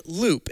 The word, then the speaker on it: Loupe: Well, I have another question on this topic, because you weren't always called Loop. And for the listeners out there, Loop is spelled LOUPE. For context. – Yes, loop. Like control loop.